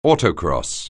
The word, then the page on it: autocross: anglais